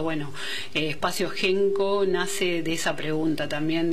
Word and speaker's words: Henko: Déjanos tus comentarios, tus mensajes, y si no, lo podés hacer también directo a Instagram, a @espacio.genko.ok, que Genko se escribe H-E-N-K-O,